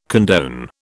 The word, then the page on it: condone: British English